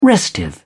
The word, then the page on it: restive: British English